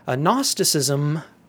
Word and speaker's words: Gnosticism: It's spelled G-N-O-S-T-I-C-I-S-M, Gnosticism. The G is silent. It comes from the Greek word gnosis, G-N-O-S-I-S, which is the word for knowledge.